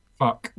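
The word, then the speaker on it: Phuq: Fuck. – Well, it's... Yeah. Yeah. A P-H-U-Q. – Yeah. – pronounced fuck, and what's an endless nameless?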